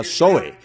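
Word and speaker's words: Zoe (ζωή): Y las dos palabras griegas y la vida de Dios o vida eterna se pronuncia Zoe, Z-O-E, Zoe, vida.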